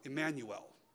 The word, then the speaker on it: Immanuel: Immanuel, or as we pronounce it now, Emmanuel. When we say Emmanuel, that's just the Hebrew Immanuel.